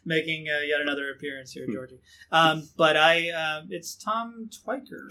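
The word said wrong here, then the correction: Tykwer: Tom Tickver. – Tickver. Okay, that's how it is. Yeah, I never heard it pronounced.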